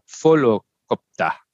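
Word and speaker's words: Volocopter: But I think it would be an F sound at the beginning, not a V. I wasn't looking at the article, but it starts with a V, so it's a folokopter.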